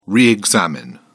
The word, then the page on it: re-examine: British English